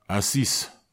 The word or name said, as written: asis